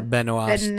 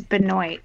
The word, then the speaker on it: Benoist: You mean that's Melissa Benoist. – It's Benoit. She doesn't pronounce it French.